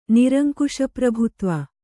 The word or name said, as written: ನಿರಂಕುಶಪ್ರಭುತ್ವ